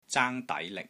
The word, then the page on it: ZAANG1 DAI2 LIK6: Cantonese